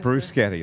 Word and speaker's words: bruschetta: Yeah, bruschetta. – Bruschetta, you're supposed to say. – If we're pronouncing it correctly.